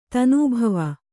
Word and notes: ಧುನಿಬಾವಾ: dhuni bāva

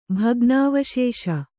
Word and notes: ಭಗ್ನಾವಶೇಷ: kannada

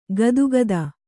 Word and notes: ಗದುಗದ: gadugada

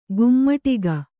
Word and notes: ಕೈಮಡಗು: kai maḍagu

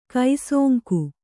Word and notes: ಕುಸಂಗ: kannada

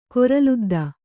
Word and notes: ಕೊರಲುದ್ದ: koraludda

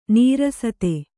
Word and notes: ನೀರಸತೆ: nīrasate

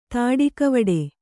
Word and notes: ತಾಡಿಕವಡೆ: tāḍikavaḍe

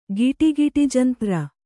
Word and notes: ಗಿಡಿಗಿಡಿಜಂತ್ರ: Kannada

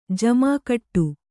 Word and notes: ಜಿಮಿಗುಡು: kannada

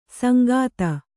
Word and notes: ಸಂಗಾತ: sangāta

